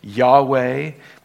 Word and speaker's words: Yahweh: That word is Yahweh. We pronounce it that way.